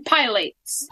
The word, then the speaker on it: pilates: Plates and pilates. – Put the accent on the second syllable.